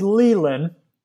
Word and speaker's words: Lelan: It's Lelan. – Lelan, sorry, yeah, man. – Which is, it's a very English pronunciation, so that's not a problem.